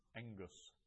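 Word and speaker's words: engus (ἐγγύς): So hemera is day, doxa, glory, zoe, life, erotao, I ask, exodus, and engus. Near. Remember that a double gamma is pronounced as an NG.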